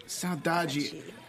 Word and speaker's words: Saudade: Saudade.